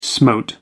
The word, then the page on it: smote: American English